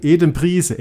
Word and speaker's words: Adam Price: Ja, der, der spricht sich Edem, Edem Priese, Edem Priese.